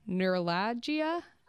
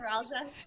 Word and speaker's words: neuralgia: But they wanted to ask if you guys can help with trimeningeal neuralgia. Am I saying that right? I know that I'm pronouncing that. – Neuralgia. – Yeah, neuralgia. Okay, there we go.